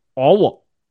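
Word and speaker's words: Aage: según Wikipedia que el nombre del hijo de Bohr se pronuncia Owo.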